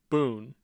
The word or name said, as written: boone